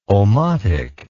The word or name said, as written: omotic